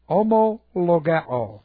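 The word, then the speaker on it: homologeo (ὁμολογέω): In the book of Revelation, now this is interesting, in the book of Revelation, homologeo, okay, this is H, we're reading H-O, M-O-L-O-G-E, long O, homologeo.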